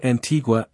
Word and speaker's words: Antigua: About Antigua and Barbuda. Antigua, pronounced Antigua, and Barbuda, Barbuda, is located in the heart of the Caribbean Sea.